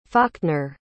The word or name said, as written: faulkner